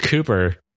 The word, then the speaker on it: Cowper: The Cowper gland. – Ew. It's pronounced Cooper. You Philistine.